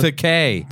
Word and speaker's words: Takei: It's Takei.